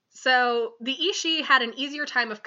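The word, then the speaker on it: aos sí: So, this is another one that I had to spell phonetically because it's spelled A-O-S-S-I, but it's apparently pronounced Ishii.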